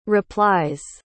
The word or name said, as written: replies